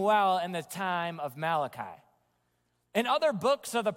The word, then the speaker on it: Malachi: Maybe some pronounce it Malachi. Maybe the Italians in the room pronounce it Malachi. It's Malachi.